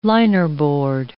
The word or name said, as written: linerboard